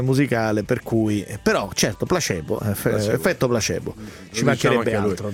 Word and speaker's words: Placebo: Guardate che placebo si pronuncia placebo con C ed E, e non placebo perché non è inglese ma latino.